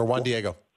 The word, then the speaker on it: Juan Diego: Dan Lynch, or Juan Diego.